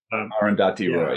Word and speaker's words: Arundhati Roy: By, and I'm probably going to mispronounce this name also, Arundhati Roy. – Arundhati Roy, yeah.